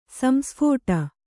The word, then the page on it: ಸಂಸ್ಫೋಟ: Kannada